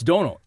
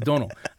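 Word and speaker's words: Domhnall: It's Donal. It really is Donal. I know it's spelled all fucked up, but he even said in an interview, he said it's pronounced like tonal, but with a D. And he's like, he's like, Donal. And he's like, the M is in there just to confuse Americans.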